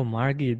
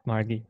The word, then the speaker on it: Margie: It's the Australian pronunciation is Margie with a hard G. oh, Margie. Yeah, thank you so much. okay. All right, Margie.